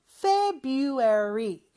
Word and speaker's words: February: 물론 February라고 철자는 되어 있지만 그 R 발음, 첫 번째 R 발음은 하지 않는다고 했어요. February 이렇게 발음하면 되고요.